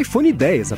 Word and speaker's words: iPhone X: E por último, no evento, a Apple anunciou o tão aguardado e bastante vazado iPhone X, né, que se escreve iPhone X, mas é iPhone X, apesar de achar que todo mundo vai falar errado.